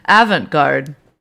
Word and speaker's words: avant-garde: Avant-garde.